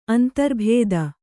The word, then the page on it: ಅಂತರ್ಭೇದ: antarbhēda